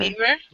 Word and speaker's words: Naver: Naver. Am I saying that right? Naver. – Naver.